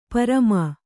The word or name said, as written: ಪ್ರೇಮಿ